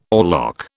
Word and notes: oarlock: British English

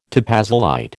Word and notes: topazolite: American English

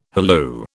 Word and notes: halloo: British English

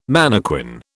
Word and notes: mannequin: British English

